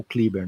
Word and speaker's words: Kleber: É, e, o Kleber, né? Que é o jeito certo de falar e que é o jeito que o, o, o patrão fala, né?